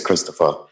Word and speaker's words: Xopher: It's Christopher. It's like I always say, it's like Xmas, where instead of C-H-R-I-S-T, you put X and then the mus. Same thing with Christopher, C-H-R-I-S-T.